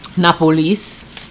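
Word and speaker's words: Napoli: Napolis is how they pronounce it.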